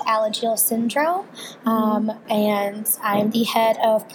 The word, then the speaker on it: Alagille syndrome: Alagille syndrome.